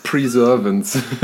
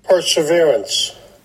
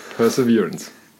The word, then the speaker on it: Perseverance: Ich glaube, die häufigsten Alternativen war Perseverance und Preservance, die ich gehört habe. Ja. Aber für alle, die es uns nicht glauben, dass wir es richtig aussprechen, hier kommt einmal so klingt es. – Perseverance. – Also, Perseverance.